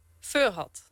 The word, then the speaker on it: Furhat: Heet hij nou Guad? – Furhat. – Furhat? – Ja. Okay. Nou, hij heet eigenlijk Furhat van Furhat Robotics.